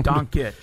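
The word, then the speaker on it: Dončić: There's a little apostrophe mark over the second C, which tells me to pronounce that with the ch sound. – So that's why I go Doncic.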